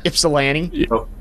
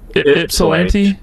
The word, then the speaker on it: Ypsilanti: Ypsilanti. – Ypsilanti. – Ypsilanti.